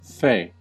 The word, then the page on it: fey: American English